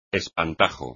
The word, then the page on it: espantajo: espagnol